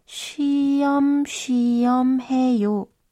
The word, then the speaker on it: 쉬엄쉬엄해요: Sprechen Sie bitte nach shiom shiom he yo. Ich wiederhole. shiom shiom he yo.